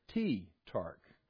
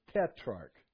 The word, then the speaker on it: tetrarch: I've always said tetrarch, but it's tetrarch. So if you don't say it right, catch up. Okay.